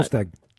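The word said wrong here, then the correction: Solskjær: The clarity is that Ole Gunnar Solskjaer has left his role as Manchester United manager.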